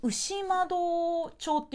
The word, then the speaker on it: 牛窓: 牛窓って書いて、はい、牛窓町って読むんですか